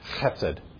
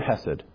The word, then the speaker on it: chesed: If you're going to pronounce it properly in Hebrew, you've got to go, chesed. It's this word chesed.